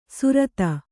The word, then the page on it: ಸುರತ: surata